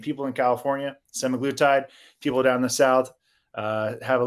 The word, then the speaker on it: semaglutide: So you're telling us that it's not smeglitude, because we've been pronouncing it smeglitude for almost a year. – Mm-hmm. Yep. – Oh yeah. I mean, it's semaglutide, but then there's some people will call it semaglutide.